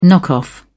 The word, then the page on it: knock off: British English